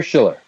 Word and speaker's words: Schueller: Don't pronounce it S-C-H-I-L-L. You pronounce S-C-H-U, right? – It's spelled S-C-H-U-E. L-L-E-R, but it's pronounced Schueller, like S-C-H-I-L-L-E-R.